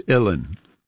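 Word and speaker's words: uilleann: It's Illin. Like chillin, illin.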